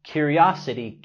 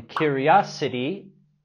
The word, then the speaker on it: curiosity: Remember, curiosity killed the cat. No fisgoneas. Recuerda, curiosidad mató el gato. La T en curiosity lo pronunciamos tal cual la R suave en español.